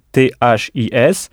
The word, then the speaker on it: this: Alors maintenant, comme Michel Thomas nous l'a appris, si ce et cet se disent this en anglais, this qui s'écrit THIS, et il faut bien prononcer avec la langue entre les dents, this.